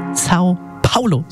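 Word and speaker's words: São Paulo: Sao Paulo, so spricht man es aus.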